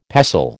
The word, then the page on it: pestle: American English